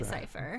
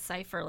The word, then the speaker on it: Sypha: It's spelled S-Y-P-H-A. It's a very strange... – In the series, they say Cypher. – Fair enough. – Alucard says Cypher like a million times, so...